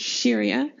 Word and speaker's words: Siria: It's spelled S-I-R-I-A, but it's pronounced Syria.